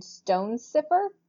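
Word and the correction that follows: Stonecipher: Cipher, yeah. – Stone Cipher.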